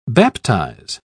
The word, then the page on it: baptise: British English